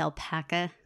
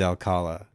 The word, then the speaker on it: Alcala: Alcala, Alpaca, something like that. – I do not believe it's Alpaca. – It is Alcala.